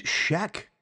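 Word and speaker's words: Schaech: I think it's Sheck. I actually looked at the pronunciation. The pronunciation is S-H-E-K when they put it on, like, when you pronounce it. So it's like Shrek, yeah? – Like Shrek, but without the R.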